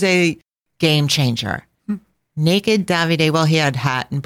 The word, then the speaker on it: Davide: Davide is the correct pronunciation, in bronze.